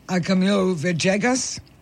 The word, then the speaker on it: Camilo Villegas: And in this case, I want to talk about Camilo Villegas.